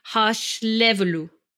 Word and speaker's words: Hárslevelű: Well, I would say Hárslevelű. – Harsh Levelu.